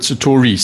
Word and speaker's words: Satori: Because I call it Satori, you call it Satori. Same thing though, hey? – Same thing.